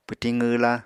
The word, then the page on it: pwtiñ qwwla: Apatani